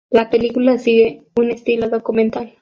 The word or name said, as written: estilo